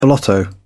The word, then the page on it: blotto: British English